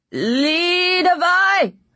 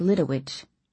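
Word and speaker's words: Lidewij: Behind it, I heard a stammering, reedy voice shout, Lidovai! – Until then, I'd pronounced his assistant's name like Lidowich.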